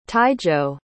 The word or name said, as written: taijo